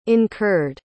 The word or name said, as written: incurred